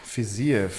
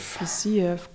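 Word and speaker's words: Fiziev: Fisiev. – Fisiev, gut ausgesprochen, mein Freund. Sehr, sehr gut ausgesprochen.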